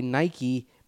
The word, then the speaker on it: Nike: Nike was a Greek goddess or god? – I believe goddess. – I think so, too. So there you go. – Yes. Different etymology there. But still, that's a good thought. – Yeah. It's the only one, I think, that doesn't like need that. A Y to be pronounced, to pronounce the E.